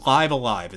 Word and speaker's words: Live A Live: I mean, Live Alive is what it's called. I've never actually knew how to say this game. It's Live Alive. Is how I never knew how to say its name either. Yeah. – Apparently, it's Live Alive.